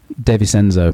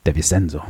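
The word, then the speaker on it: De Vicenzo: And that was DiVincenzo in 67, yeah? – DeVincenzo, apparently that's how you pronounce it. DeVincenzo.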